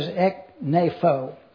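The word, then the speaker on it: ekneipho (ἐκνήφω): What I like to do is to take the Greek word and find out how to pronounce it. You'll notice that it is the aorist active imperative, second person plural, of E-K-N-E-P-H-O. And that is ekneipho.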